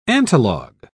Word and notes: antilog: British English

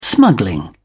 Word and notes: smuggling: British English

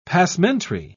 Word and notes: passementerie: British English

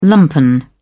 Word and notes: lumpen: British English

